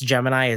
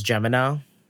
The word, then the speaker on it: Gemini: I also would like to address that I do know that last week I pronounced Gemini as Gemino.